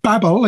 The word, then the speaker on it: Babel: Well, the story of the Tower of Babel, or Babel, if any Americans are listening, that's how they say it.